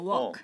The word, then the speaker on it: walk: 오히려 저는 일하다가 네. – L이 없는 것처럼 발음해야 될 것 같았는데. – 아, 그래요? – 반대로 걷다가 walk. – 그렇죠. – 일하다가 work.